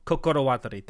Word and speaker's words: Kokorowatari: Koyomi shows up and he thrusts the Kotowatari? – Kokorowatari? – Kokorowatari, thank you.